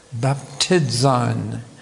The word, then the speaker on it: baptizein: Baptizein is how it's pronounced by the Greeks.